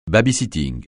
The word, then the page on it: baby-sitting: francais